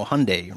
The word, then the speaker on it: Hyundai: Hyundai Genesis Coupe. – No, Hyundai. Rhymes with Sunday.